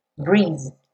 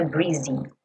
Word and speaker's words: breeze: Breeze. Percebe que esse E final não é pronunciado, tá? Então não é breezy breeze Keep me warm.